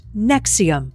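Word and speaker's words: NXIVM: Executive Success Programs was part of a company with a strange name, pronounced Nexium.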